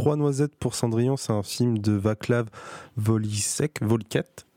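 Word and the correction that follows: Vorlíček: Je ne sais pas exactement comment on prononce ça à la fin. Un film de 1973 qui est aussi lié en partie à la zone de l'URSS, il a été produit. – Vorlitschek, mon cher.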